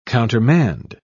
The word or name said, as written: countermand